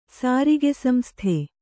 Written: ಸಾರಿಗೆಸಂಸ್ಥೆ

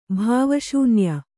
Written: ಭಾವಶೂನ್ಯ

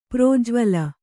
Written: ಪ್ರಜ್ವಳ

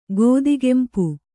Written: ಗದಗಂಪ

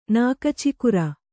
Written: ನಾಕಚಿಕುರ

